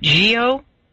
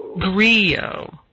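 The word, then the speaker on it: griot: You now there's a, and I'd never had seen this term before, and I don't know if I'm pronouncing it correctly, but is it Gio? – Grio. – And yeah, as it's like kind of like a French pronunciation.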